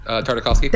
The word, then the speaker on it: Tartakovsky: Tartakovsky.